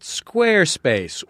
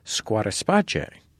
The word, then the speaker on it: Squarespace: This week, it's also supported by our friends at Squarespace, which they pronounce, interestingly, Squatterspace.